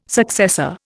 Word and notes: successor: Indian

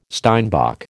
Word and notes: steinbock: American English

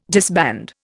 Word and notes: disband: Indian

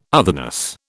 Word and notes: otherness: British English

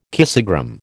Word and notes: kissogram: American English

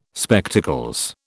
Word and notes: Spectacles: British English

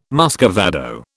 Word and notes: muscovado: British English

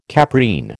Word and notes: caprine: American English

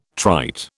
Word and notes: trite: British English

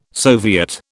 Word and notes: soviet: British English

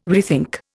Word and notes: rethink: Indian

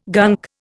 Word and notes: gunk: Indian

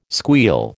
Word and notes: squeal: American English

